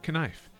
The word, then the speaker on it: knife: Now, one thing we do know, in the days of Old English, words like knife that began with a K pronounced letter K, so it's pronounced knife. It was fully voiced in Old and Middle English, but it disappeared by 1750 after about a century of weakening and fading. Sibling languages like German and Dutch continue to pronounce the K sound.